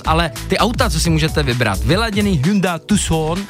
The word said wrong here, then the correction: Hyundai: To se vyslovuje jinak. – Je to tak, je to tak. – Hyundai, jak v té reklamě, Hyundai.